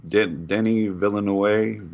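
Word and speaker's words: Denis Villeneuve: I saw, how do you pronounce his name? Denny Villeneuve? – Yeah, exactly.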